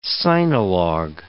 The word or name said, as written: sinologue